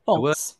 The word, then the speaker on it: folx: Folks. – The word is F-O-L-X, and I've never said it out loud. – It's like F-O-L-K-S. – Folks.